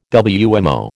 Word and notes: WMO: American English

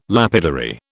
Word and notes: lapidary: British English

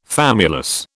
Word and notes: famulus: British English

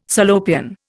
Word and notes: Salopian: Indian

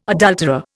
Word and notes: adulterer: Indian

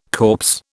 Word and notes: corpse: British English

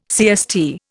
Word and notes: CST: Indian

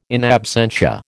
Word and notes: in absentia: American English

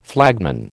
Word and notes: flagman: American English

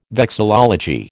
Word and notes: vexillology: American English

